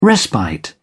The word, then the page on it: respite: British English